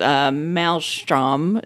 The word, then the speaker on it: maelstrom: But then Gucci, the finest fashion label of our day, got caught up in this maelstrom, is that how you pronounce it? – Yeah, that's correct. – of blackface because there was...